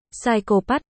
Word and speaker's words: psychopath: Phải đọc là, psychopath, anh nó cố gắng đọc thật rõ, cho nó nghe, vậy còn chữ. P, psychopath, ở đầu vứt đi đâu, nó chu mỏ bướng bỉnh, chữ.